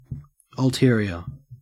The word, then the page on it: ulterior: Australian English